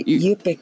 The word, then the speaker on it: Yupik: Yupik. It's actually Yupik. Because when you say Yungtun, you're saying in Yupik. So it's like an incomplete statement. You know what I mean? You're just saying in Yupik, and you know?